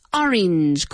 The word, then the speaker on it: orange: Orange, como si la A fuera una I. Orange, orange, okay? Emphasis on the first syllable, orange.